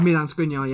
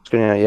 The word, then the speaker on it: Skriniar: Key player is, I can't say his name, Skriniar? – Milan Skriniar, yeah. – Skriniar, yeah.